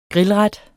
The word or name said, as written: grillret